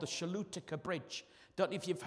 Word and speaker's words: Choluteca: Okay, it's spelled the C-H-O-L-U-T-E-C-A, the Shalutica Bridge.